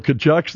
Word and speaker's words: Tkachuk: So you got a couple Tkachuks there. That just bolstered the Florida Panthers. – How do you pronounce that? – Tkachuk. – Because you sure don't spell it like that.